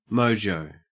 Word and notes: mojo: Australian English

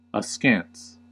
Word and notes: askance: American English

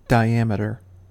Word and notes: diameter: American English

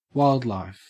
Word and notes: wildlife: Australian English

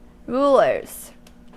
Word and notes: rulers: American English

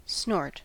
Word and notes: snort: American English

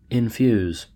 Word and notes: infuse: American English

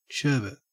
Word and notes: sherbet: Australian English

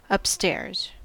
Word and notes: upstairs: American English

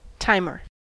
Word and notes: timer: American English

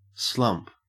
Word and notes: slump: Australian English

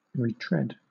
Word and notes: retread: British English

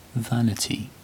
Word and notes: vanity: British English

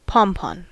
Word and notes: pompon: American English

